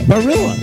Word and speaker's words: Barilla: Barilla. That's spelled B-A-R-I-L-L-A.